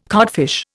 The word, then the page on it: codfish: Indian